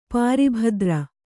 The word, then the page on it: ಪಾರಿಭದ್ರ: pāri bhadra